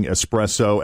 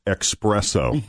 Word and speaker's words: espresso: Yeah, 45% of coffee snobs will judge you for pronouncing espresso as expresso.